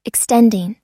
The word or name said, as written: extending